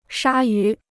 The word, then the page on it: 鲨鱼: shā yú